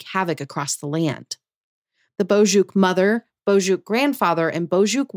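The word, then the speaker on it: Boçuk: So Bozhuk is the name. It is a fun word. It's spelled B-O-C-U-K, though, but it's pronounced Bozhuk.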